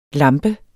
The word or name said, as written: lampe